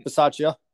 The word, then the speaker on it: Bisaccia: I mean, Rich Bisaccia, that's how you pronounce his last name, I believe. – Yep, Bisaccia.